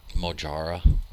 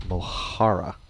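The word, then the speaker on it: mojarra: Mojara. – Mojara. – Mm-hmm. Jalapeno. – No. And you have to roll the R, too, because it's a double R.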